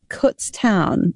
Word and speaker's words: Kutztown: Can you tell me about Kutztown? Is that how you say it? – Yes, you're the only person to actually say that right first time.